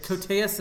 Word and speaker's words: Koteas: And we didn't mention that there's an Elias Koteas, how do you say his name? – Koteas in the movie.